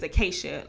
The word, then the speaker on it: Acacia: My actual name is Acacia, like the tree. And so it's a C. It's A-C-A-C-I-A.